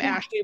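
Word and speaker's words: Ashlee: Like I'm Ashley with L-E-E, not L-E-Y.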